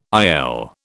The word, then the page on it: IL: British English